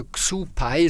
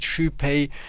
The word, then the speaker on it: Xu Pei: Also Xu Pei, so klingt das, wenn man das jetzt so ausspricht, wie man es liest, Xu Pei, dann ausgesprochen.